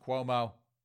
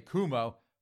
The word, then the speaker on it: Cuomo: And yes, folks, again, I do know it's pronounced Cuomo. I just like to say Kumo to insult that clown car of a family.